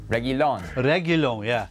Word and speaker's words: Reguilón: Doherty along with Gullian. How do you pronounce it again? – Reguilón, yeah.